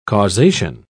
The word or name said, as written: causation